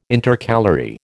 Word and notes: Intercalary: American English

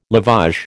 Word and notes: lavage: American English

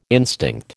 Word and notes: instinct: American English

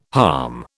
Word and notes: harm: British English

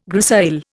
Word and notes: grisaille: Indian

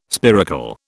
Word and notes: spiracle: British English

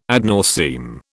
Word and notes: ad nauseam: British English

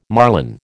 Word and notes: marlin: American English

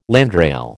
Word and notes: landrail: American English

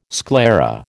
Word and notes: sclera: American English

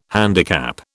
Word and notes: handicap: British English

